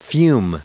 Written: fume

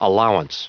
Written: allowance

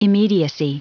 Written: immediacy

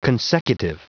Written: consecutive

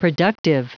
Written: productive

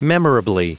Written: memorably

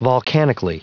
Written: volcanically

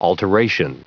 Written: alteration

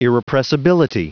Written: irrepressibility

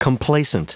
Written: complacent

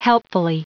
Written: helpfully

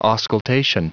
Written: auscultation

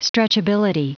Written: stretchability

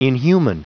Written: inhuman

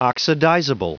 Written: oxidizable

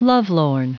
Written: lovelorn

